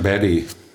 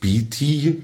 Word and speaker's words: Beatty: Ja, der Amerikaner sagen Betty. Amerikaner sagen Betty. – Okay. – Ja. Also eben nicht Beatty.